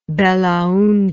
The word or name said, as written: belaunde